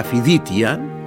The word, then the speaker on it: φιδίτια: Τα συνήθεια δέσματα που προσφέρονταν στα φιδίτια, σας θυμίζω φιδίτια είναι τα συσίτια, και εδώ πρόκειται για τη λέξη φιλίτια, διότι το λάμδα στη λακωνική γλώσσα μεταξύ δύο φωνιέντων προφέρεται δέλτα.